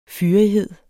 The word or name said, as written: fyrighed